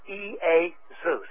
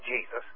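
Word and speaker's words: E-A-Zeus (Ἰησοῦς): And it's pronounced E-A-Zeus. It ain't pronounced Jesus. It never was,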